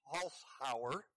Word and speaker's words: Holzhauer: I haven't watched it in years, but I read that Jeopardy champ James Holzhauer, that's how you pronounce it, just ended his record-breaking run of 32 games in a row and earned $2.4 million on Jeopardy.